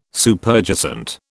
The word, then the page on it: Superjacent: British English